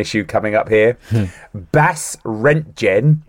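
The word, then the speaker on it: Basse-Rentgen: B-A-S-S-E hyphen R-E-N-T-G-E-N. – B-A-S-S-E. – Space R-E-N-T-G-E-N. – Oh, yeah, Bas-Rentgen. – There you go, Bas-Rentgen.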